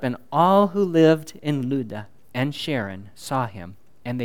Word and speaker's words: Lydda: Lydda. That's how you say it, Lydda. If you're used to saying Lydda, go ahead and say Lydda, just don't say Lydda.